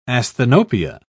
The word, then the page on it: asthenopia: British English